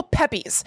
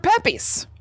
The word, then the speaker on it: Pepys: From Mr. Pepys. Or Pepys. It's P-E-P-Y-S. Pepys? – Pepys. – I am Samuel Pepys. – It's like the way Kramer would pronounce that name. You know, like he says Ramirez instead of Ramirez.